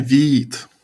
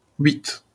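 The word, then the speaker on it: Vite: French word for quick pronounces at wheat. Like wheat.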